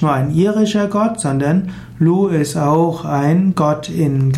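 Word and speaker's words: Lugh: Lu geschrieben mit G am Ende oder GH ausgesprochen Lu ist ein wichtiger Gott der irischen Mythologie.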